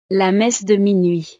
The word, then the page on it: la messe de minuit: frances